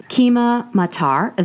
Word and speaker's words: Keema matar: Keema matar, is that how you pronounce it? – Yeah, it does, yes.